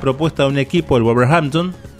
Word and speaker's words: Wolverhampton: pero pronunciado es Wolverhampton.